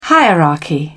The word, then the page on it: hierarchy: British English